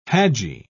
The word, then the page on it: hajji: British English